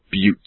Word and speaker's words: buttes: and I think I pronounced the particular rock formations as butts, and I've been told they are pronounced buttes, so I do apologise for that.